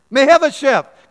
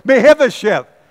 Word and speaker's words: Mephibosheth: And Celeste says, I'm not sure you're pronouncing that right. And I said, only one person knows. It's his mother. And that guy's name is Mephibosheth. Now, I looked on the web, and you can get all kind of pronunciations. People will pronounce this for you. I like mine better.